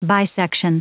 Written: bisection

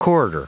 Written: corridor

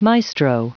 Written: maestro

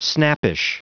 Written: snappish

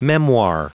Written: memoir

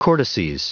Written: cortices

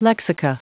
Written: lexica

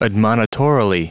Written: admonitorily

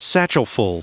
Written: satchelful

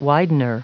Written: widener

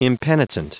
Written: impenitent

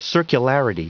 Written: circularity

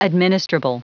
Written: administrable

